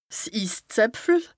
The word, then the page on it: 's Isszäpfel: Bas-Rhin; Strasbourg